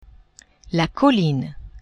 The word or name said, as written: colline